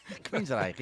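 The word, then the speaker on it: Queensrÿche: Queensrÿch, ja, dat klopt.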